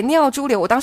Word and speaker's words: 尿潴留: 否则会造成那个叫尿潴留。 – 尿潴留，对，尿潴留。我当时还去查了那个字怎么念。